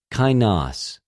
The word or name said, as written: καινος